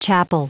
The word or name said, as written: chapel